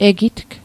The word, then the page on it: egitg: Mi'gmaq